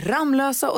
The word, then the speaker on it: Ramlösa: Det heter alltså Lovika, det heter Ramlösa och det heter Kosta.